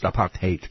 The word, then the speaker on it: apartheid: So, It's actually pronounced apartheid.